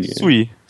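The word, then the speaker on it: Žulj: durch Zul, ich weiß gar nicht, wie man ihn genau ausspricht, Robert Zul. – Sui, spricht man glaube ich aus, Sui.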